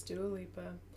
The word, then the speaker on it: Dua Lipa: Is that actually how you say it? – No. Don't listen. – I was going to say, I thought, oh my god, was I saying it wrong? – Pretty sure it's Dua. – It is. It's Dua Lipa.